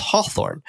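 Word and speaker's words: Hathorne: One of the real life judges of the Salem witch trials was named John Hawthorne, pronounced Hawthorne. His descendants, the famous historical novelist Nathaniel Hawthorne, changed his name to be spelled like it sounds.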